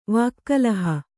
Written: ವಾಕ್ಕಲಹ

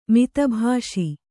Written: ಮಿತಭಾಷಿ

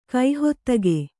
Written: ಕೈಹತ್ತುಗೆ

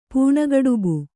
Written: ಪೂಣಗಡುಬು

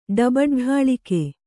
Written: ಡಬಡ್ಢಾಳಿಕೆ

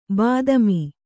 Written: ಬಾತ್ಮಿ

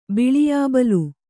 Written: ಬಿಳಿಯಾಬಲ್

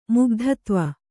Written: ಮುಗ್ಧತ್ವ

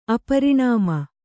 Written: ಅಪರಿಣಾಮ

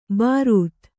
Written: ಭರಿತ

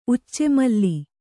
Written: ಉಚ್ಚೆಮಲ್ಲಿ

